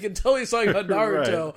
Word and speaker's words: Naruto: Also, Michael B. Jordan pronounces Naruto Nuroto.